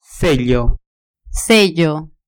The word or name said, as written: sello